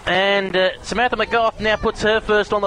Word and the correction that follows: McGough: McGeo, pardon me. I keep saying it wrong.